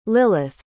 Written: lillith

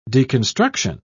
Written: deconstruction

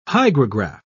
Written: hygrograph

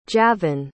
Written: javan

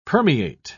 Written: permeate

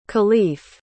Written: kalief

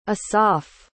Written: Asaf

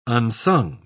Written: unsung

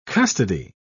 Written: custody

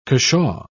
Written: cushaw

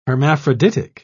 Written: hermaphroditic

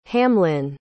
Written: hamlin

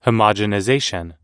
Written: homogenization

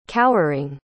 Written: cowering

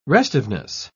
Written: restiveness

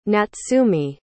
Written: natsumi

